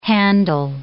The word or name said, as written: handle